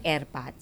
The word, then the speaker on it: ERPAT: And pre-pronounced daw siyang backwards. – Mm-hmm. – So, naging airpat. So, Father, pre-pronounced backward, kaya naging airpat.